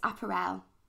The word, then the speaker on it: Apparelle: So is it Apparelle? Apparelle. – People say different things. – Yeah, because I was like, it's Apparelle. Am I saying it right? – Right, yeah.